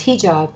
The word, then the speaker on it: hijab: I think it's hijab as opposed to hijab. Stress on the first syllable.